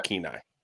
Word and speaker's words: Kenai: So we've been researching lately because Sarah applied for a position in, how do you say it again? Kenai. – Yeah. Kenai.